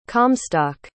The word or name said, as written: comstock